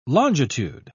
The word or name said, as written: longitude